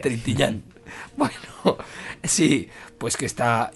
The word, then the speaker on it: Trintignant: Y está, por supuesto, Jean-Louis, a ver si lo puedo pronunciar bien, Trintignant. – Trintignant. – Trintignant. – Sí. Trintignant. Con ñ. Trintignant.